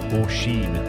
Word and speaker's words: Oisin: They recently visited Ireland after the lockdown and were mortified when the grandparents turned around and said it's actually pronounced Oisin.